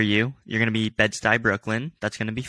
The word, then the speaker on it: Bed-Stuy: Bed-Stuy.